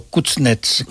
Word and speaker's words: Kuznets: El señor bielorruso Kuznets, ¿se pronuncia bien así? – Simon Kuznets, sí.